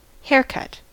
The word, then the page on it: haircut: American English